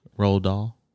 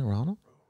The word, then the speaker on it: Roald Dahl: Isn't it Ronald? – No. – Roald. – O-R-O-A-H-L. – I remember because I could never pronounce it when I was a kid. – What is it called? – The Witches. – The author is called Roald Dahl. – And R-O-A-L-D-D-A-H-L.